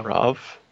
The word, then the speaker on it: Rav: Rav pronounced like Rav. So. – Rav? Like Khan? Rav? – No, see, that doesn't work. You put an A in front of an H, it doesn't make an Av sound. – Ah. – Like Rav. You write out Ah, it makes that sound. – Yeah. – Like a long scream. – Nope. There's no, and for that matter, just because you decide that it should be pronounced that way, no. It's Rav. I don't care.